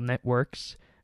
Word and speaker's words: Networx: So this is a Windows application called Networks. It's spelled with an X rather than a KS, so NETWORX.